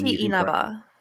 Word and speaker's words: Inaba: That's Inaba. – Well, there we go. She's done it for me. – Atsushi Inaba, the president of Platinum Games.